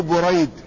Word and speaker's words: بريد: قال: وكذلك من الأسماء القريبة من ذلك ابن حفيد الأشعري، لكن اسمه بريد بضم أوله وفتح الراء مصغر. ولهما،